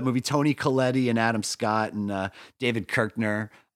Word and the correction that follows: Koechner: David Koechner. You're the only one I ever know who pronounces that correctly.